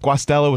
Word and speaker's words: Guastella: So I have a first-time guest today on the show, Joseph Guastella with my real estate team.